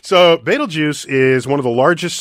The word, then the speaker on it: Betelgeuse: Betelgeuse, really? – Betelgeuse, and that's how you pronounce it, Betelgeuse.